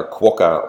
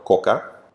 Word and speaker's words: Quokka: So you spell it Quokka, Quokka, and you can pronounce it either Quokka or Quokka.